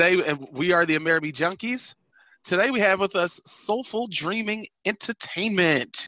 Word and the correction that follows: Social Dreaming Entertainment: No. Social Dreaming Entertainment. – I always say it wrong, and I've not been practicing. That's the sad part. I'm sorry. Social Dreaming Entertainment.